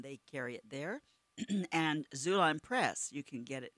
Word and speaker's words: Xulon: And Zulon, by the way, is spelled X-U-L-O-N. L-O-N. Oh, boy. X-U-L-O-N.